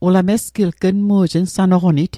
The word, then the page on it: nsanoqonit: Mi'gmaq